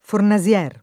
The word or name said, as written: Fornasier